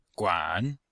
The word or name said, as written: guan3